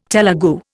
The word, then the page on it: Telegu: Indian